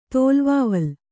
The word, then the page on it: ತೋಲ್ವಾವಲ್: tōlvāval